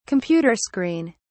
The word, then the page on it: computer screen: British English